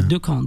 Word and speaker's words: Decaen: Donc, Decan, ça s'écrit? – D-E-C-A-E-N. – C-A-E-N. – Voilà, Decan. D-E-C-A-E-N.